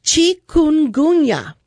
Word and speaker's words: Chikungunya: Chikungunya.